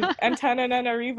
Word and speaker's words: Antananarivo: I lived in Madagascar in Antananarivo, or like, I'm pretty sure I butchered the capital.